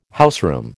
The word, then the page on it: houseroom: American English